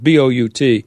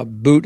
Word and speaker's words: Bout: But the more people heard about who this Victor Boot guy is, I've heard his name pronounced both ways. It's B O U T uh, Boot and Bout. We'll call him Boot.